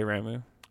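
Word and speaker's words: Ramuh: Is it Ramu? The Thunder one? I don't know how they ever pronounce it. – I always it. – Say Ramu.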